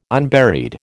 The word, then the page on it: unburied: American English